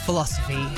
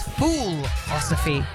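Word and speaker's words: Foolosophy: That is Jamiroquai Love Philosophy here on Drive. I should make sure I pronounce that correctly because it's not philosophy, of course, it's fool-osophy.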